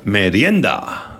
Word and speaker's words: merienda: How do I say that? Merienda? – Merienda. – Oh, I will say that.